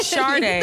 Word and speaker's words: Sade: This is Sade. Funny. – Oh Sade. wow, you got it right. Sade. the millennial yeah. got like, it right.